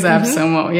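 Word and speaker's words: AppSumo: Epsumo, I think. I don't know how to pronounce it. – is Epsumo, yeah.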